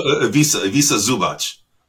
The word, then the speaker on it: Ivica Zubac: Ivica Zubac. Is that how you pronounce it? – Well, Ivica Zubac.